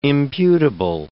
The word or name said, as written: imputable